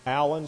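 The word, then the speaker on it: Alan: My name, Mark Allen, spelled with one L, A-L-A-N, Newbold, is in that book.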